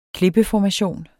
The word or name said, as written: klippeformation